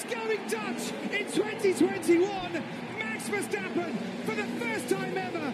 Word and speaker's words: Max Verstappen: Max Verstappen.